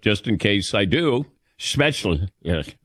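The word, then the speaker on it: Spätzle: It's not Spetzel. It's Spetzla. – Spetzla.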